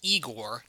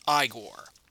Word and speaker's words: Igor: Yes, it's pronounced Igor, not Igor.